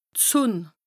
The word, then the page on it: d' Sùnn(e): Bas Rhin; Strasbourg